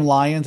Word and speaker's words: Lyons: So my last name Lyons, it's spelled with a Y, like Why?